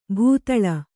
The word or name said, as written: ಭೂತಾಳ